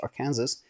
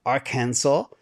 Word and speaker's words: Arkansas: So, Northwest Arkansas, or I always say that wrong, Arkansas, is providing remote workers with an incentive in the form of $10,000 in Bitcoin if you relocate to the region.